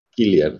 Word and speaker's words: Cillian: Sí, sí es grandísima antes de, meternos en, sus, en nuestras favoritas, platícanos un poco de Gillian Murphy, que yo siempre le he dicho Cillian Murphy porque así se escribe con C, por si lo Exacto. Pero, pero ahorita este, me ilustró y me dijo que este, en irlandés se dice... – Killian, ajá.